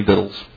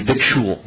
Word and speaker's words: victual: neither had they prepared for themselves any victuals. I hate that word, because it looks like it should be victual. And it is pronounced victual.